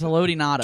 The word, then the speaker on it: Haloti Ngata: Nose tackle in the game is, but since you can't pronounce his name, his name is Haloti Ngata.